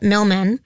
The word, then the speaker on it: Millman: John Millman, like Milkman, but Millman, was defeated by Djokovic.